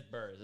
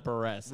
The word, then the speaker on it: Buress: That's Buress. It's not Buress.